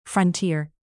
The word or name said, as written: frontier